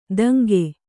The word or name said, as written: ತಂಗೆ